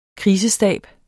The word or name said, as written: krisestab